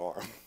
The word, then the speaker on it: Aram: And if you ever hear me playing Travis Tritt, you better know T-R-O-U-B-L-E is coming. And T-R-O-U-B-L-E is spelled A-R-A-M. That's my name, Arm.